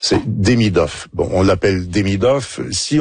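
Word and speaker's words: Demidov: Il y a un petit Demidoff. L'accent est sur la deuxième syllabe. Donc, Demidoff.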